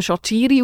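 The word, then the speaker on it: Shaqiri: Ja, oder nachher gibt es immer noch drei Varianten, weil einfach mal eines Tages der Sherdan Shakiri irgendwo gesagt hat, eigentlich würde man mich eben Shakiri aussprechen.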